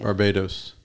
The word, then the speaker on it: Barbados: And then Barbados, is that how you say it? – Barbados.